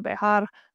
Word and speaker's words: Bexar: So obviously it's pronounced bear, but it's Bexar, Bexar barbell. For anyone who doesn't know, it's pronounced bear because it's the county we live in. So San Antonio is obviously very close to Mexico. And so it's technically Bejar, but we all just pronounce it bear.